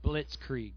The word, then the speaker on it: blitzkrieg: When several daytime attacks proved unsuccessful, the Germans executed a nighttime blitzkrieg. If that's how you say it. There you go, say that. – Blitzkrieg. – Yeah, like that.